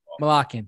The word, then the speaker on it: Malakian: Malakian, thank you.